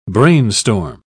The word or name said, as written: brainstorm